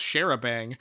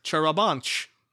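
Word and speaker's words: charabanc: Like, for example, when I read this, I didn't think, oh, that's pronounced Sharabang. I thought maybe it's pronounced Charabanch. – Or Charabank. – No, share a bang.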